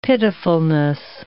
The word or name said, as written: pitifulness